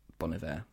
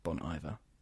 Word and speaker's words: Bon Iver: Which is someone who, everyone who regularly listens to this podcast will know, we love Bon Iver, Bon Iver. – I was so pleased that you said it right the first time and then you didn't.